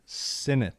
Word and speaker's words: Sinnott: Ben Sinnott.